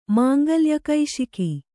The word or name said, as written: ಮಾಂಗಲ್ಯಕೈಶಿಕಿ